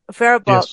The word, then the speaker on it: Faribault: Roger, was it Faribault that we were in Minnesota? Faribault? Am I getting the name right? – That's correct. – Faribault.